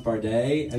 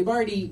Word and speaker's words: Bardey: And I like my name, but I don't like that it's Charlie and then my last name is pronounced Bardet. And so and everybody is like Charlie Bardy, which is a little too cute.